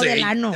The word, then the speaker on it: Delano: Pero se dice Delano, ¿no? – Ah, yo no sé. – No, Delano.